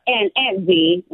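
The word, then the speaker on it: Vie: Well, we call her Aunt V, right. – Okay. You know, I just want to make sure V-I-E. V-I-E isn't Vi. Aunt Vi.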